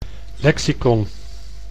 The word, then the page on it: lexicon: Dutch